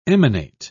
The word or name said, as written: emanate